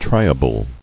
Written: Triable

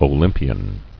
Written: Olympian

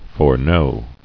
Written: Foreknown

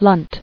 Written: Lunt

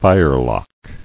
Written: Firelock

